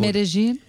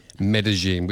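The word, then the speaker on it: Medellín: Medellin. – Medellin, which is not how you and I pronounced it last week when it had been released. – No, but that's what she says in the song.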